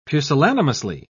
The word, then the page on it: pusillanimously: British English